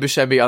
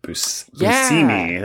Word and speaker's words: Buscemi: John said that he learned that it's actually pronounced Buscemi, and he asked Steve Buscemi why he doesn't insist that people say that. And he was like, meh. he's like, people seem to think it's Buscemi.